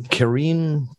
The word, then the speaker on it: Karine: Uh, it's going to be replaced by Karen Karine. There's an E on the end.